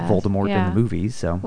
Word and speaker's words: Voldemort: She, she intended his name the last part of the name, the M O R T, to be like the French word for death. So the T is silent. She pronounces it Voldemort.